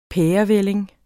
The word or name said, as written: pærevælling